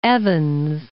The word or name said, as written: Evans